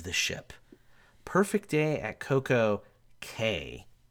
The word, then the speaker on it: Coco Cay: They don't pronounce it key like the Bahamans. – Makes zero sense. – Because in Bahama, C A Y is key. Um, Royal doesn't. They just wanted care. it to rhyme with.